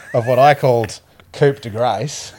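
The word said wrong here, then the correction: coup de grâce: Coop de Grace. – And what did you call it, Weitz? – Coup de Grace. – Yeah, coup de Grace.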